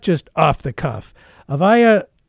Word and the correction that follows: Avia: Kushner, I hope I pronounced that right. Did I pronounce it right? Okay. – It's actually Avia. – Mm-hmm. Avia Kushner is the Forward's language columnist.